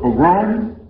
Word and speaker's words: pogrom: Concerning the Harlem incident that took place during the summer, when the citizens of Harlem were attacked in a pogrom, how do you pronounce that word? Pogrom? Pogrom. That's what it was.